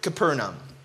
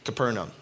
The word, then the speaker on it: Capernaum: When Jesus again entered Capernaum. Debatable on the pronunciation of that city. Capernaum. Sounds right. Capernaum? No. Capernaum. The people heard that he had come home.